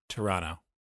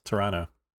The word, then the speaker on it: Toronto: Just so you don't get bullied by people online, I need you to not say the second T in Toronto. – Okay. – When you pronounce it, it's not pronounced that way by the locals. – Toronto? – Toronto.